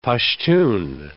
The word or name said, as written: Pashtun